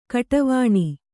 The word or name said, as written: ಘಡಾವಣೆ